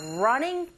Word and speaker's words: running: Now try the second listening point, which is we are running towards the end. 자, 여러분 여기서 running 할 때 learning이라고 발음하시면 안 되죠. R 사운드 낼 때는 분명히 입을 이렇게 모으신 다음에 앞으로 내밀어 보세요. 이러면서 시작하시면 돼요.